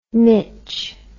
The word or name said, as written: Mich